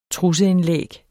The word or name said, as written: trusseindlæg